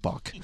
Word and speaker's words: Bach: Oh, did I say Bark? I meant Bach.